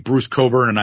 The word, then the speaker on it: Bruce Cockburn: His name is, I think, Bruce Coburn, but it's spelled Cockburn. And I said, yeah, Bruce Cockburn.